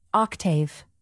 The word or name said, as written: octave